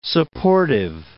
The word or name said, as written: supportive